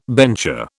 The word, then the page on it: bencher: British English